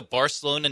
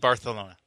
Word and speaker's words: Barcelona: FC Barcelona. – Wait, with a lisp? – No, that's not a lisp. That's the Catalonian pronunciation.